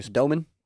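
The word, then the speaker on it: Domann: So Nebraska's JoJo Doman. Is that it? Yep, Doman. – Doman, okay. Yep,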